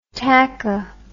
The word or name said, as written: tacho